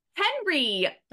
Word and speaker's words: Henri: Henry, not Henri, as it's spelled. Indeed, Henry. – Yep. Confusing, but that's what it is.